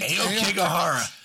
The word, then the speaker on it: Aokigahara: Aokigahara. Did I say that right? – Aokigahara.